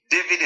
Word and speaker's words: David: David is spelled D-A-V-I-D, and Azeez is spelled A-Z or Z, you know, according to how you pronounce it.